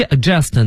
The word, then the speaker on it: adjust: 对，所以是 adjust 呢，它前面有个 d 啊，大家就不发音了。